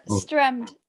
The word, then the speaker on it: Stremd: So could you tell us how to pronounce it? – Mine? – No. – Stremd. – Stremd. Okay. Did I say it right? – Nobody knows how to say it.